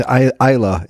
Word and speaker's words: Islay: I went to Islay, it's pronounced. I always pronounced it Islay before I went to the island and got told off by the locals.